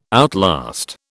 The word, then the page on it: outlast: British English